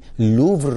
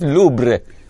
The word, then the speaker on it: لوور: نمیتونه نام یکی از معروفترین موزه های جهان در پاریس یعنی لوور رو درست تلفظ کنه. روح الله همه جوری تلفظ میکنه الا تلفظ درستش. آخرم علارغم کمک های لفظی نماینده های دیگه اشتباه تلفظ کرد و گفت لوبر با ب.